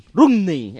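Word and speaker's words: Romney: Washington, el candidato republicano a presidencia de Estados Unidos, Rumney, ¿cómo se pronuncia, no? – Rumney, eso.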